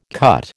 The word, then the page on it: cut: American English